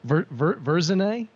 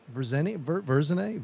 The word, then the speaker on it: Verzenay: Verzenay, Verzenay, V-E-R-Z-E-N-A-Y.